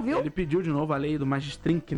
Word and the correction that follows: Magnitsky: né? Como é que fala isso? – Quê? – Magnitsky. Magnitsky. Ele pediu de novo a lei Magnitsky para o Xandão.